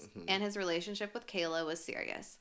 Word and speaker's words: Kala: Okay, and this person, her name is spelled K-A-L-A, but I heard it pronounced Kayla, so I'm going to say Kayla.